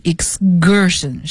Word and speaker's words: excursion: 那么这个单词呢，有同学说是不是应该念 excursion 呢，这个 C 发科的音呢，好，其实字典上会这样写，但是因为发在 S 清辅音后面重读的这种音都要浊化，所以呢就念成 excursion，是 g，像 guard 这种浊辅音。